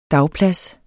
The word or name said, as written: dagplads